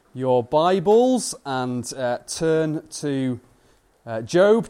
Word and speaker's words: Job: Pronunciation, Job.